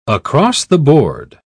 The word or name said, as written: across-the-board